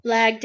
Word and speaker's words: Blag: met Blog, but did I say his name right? – Blog, you know. – Blag Dahlia.